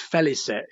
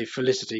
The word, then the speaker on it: Félicette: so our actual business, you know, the cats in space business, is actually uh, well, it's, it's Félicette is the uh, the correct yes. pronunciation. – It's Félicette Limited, but I just say Felicity because it looks like Felicity to me.